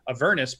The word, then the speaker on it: Avernus: I say Avernus, because I was understood in one of their videos, they said it's like saying a furnace. Say Avernus, but I think when I originally read it, I would say it like you did, Avernus.